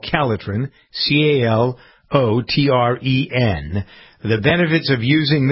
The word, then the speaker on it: Calotren: It's spelled C-A-L-O-T-R-E-N. Calitren is the product.